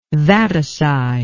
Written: vaticide

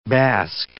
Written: bask

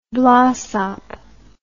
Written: blaasop

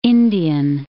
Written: Indian Ocean

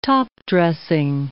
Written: topdressing

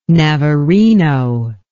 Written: navarino

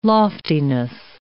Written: loftiness

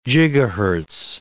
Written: gigahertz

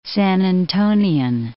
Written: San Antonian

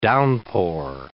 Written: downpour

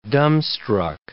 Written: dumbstruck

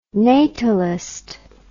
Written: natalist

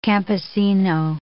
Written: campesino

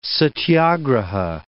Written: satyagraha

